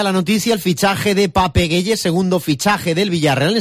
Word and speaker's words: Pape Gueye: Escuchado ya el saludo de Pape Gueye, así se pronuncia, vamos ahora a un sitio donde él jugó hace 2 temporadas, que es el Sevilla.